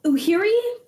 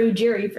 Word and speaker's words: Ujiri: Uhiri for Ujiri for sure.